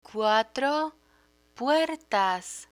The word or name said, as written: cuatro puertas